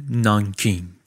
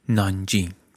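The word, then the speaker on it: نانجینگ (南京): شهر نانکینگ یا با تلفظ محلی نانجینگ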